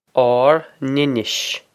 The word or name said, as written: ár n-inis